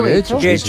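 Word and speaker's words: Getxo: Guecho.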